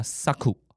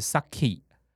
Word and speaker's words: 咲: 那发音呢，啊，是发 saku 啊，或者是 saki，好，用这两个来发音都有，都会出现。